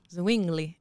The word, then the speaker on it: Zwingli: Zwingli. – It's like swinging, but with a Z. Zwingli.